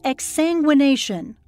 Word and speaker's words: exsanguination: It's called exsanguination, Am I saying it correctly? – Yes.